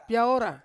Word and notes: piaora: Ansus

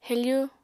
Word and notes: hefu: apatani

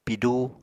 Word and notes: pidú: Apatani

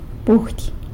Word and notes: budy: sanlucasquiavini

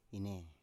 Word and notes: inei: Biak

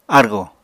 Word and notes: argò: Apatani

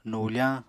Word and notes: nufañ: Apatani

